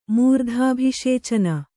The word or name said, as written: ಮೂರ್ಧಾಭಿಷೇಚನ